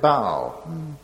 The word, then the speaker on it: Baal: I've reserved for myself 7,000 who have not bowed the knee to Baal, or as Gordon rightly pronounced it, Baal.